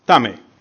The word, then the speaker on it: Tame: and the name of the town was Tame.